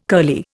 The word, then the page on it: curly: Indian